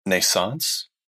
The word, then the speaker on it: NaissanceE: I would like to offer the listeners an XP to check out a game on Steam called Naissance. Pronounced like Renaissance, but like Naissance. And also another E at the end.